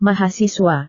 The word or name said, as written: mahasiswa